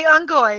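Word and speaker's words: Unggoy: I'm like, okay, so what about the Ungoy? He goes, yes, you're pronouncing those correct too.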